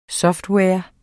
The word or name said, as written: software